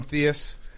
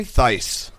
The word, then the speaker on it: Theis: I'm going to keep calling him Theus. Is that okay? – Theis.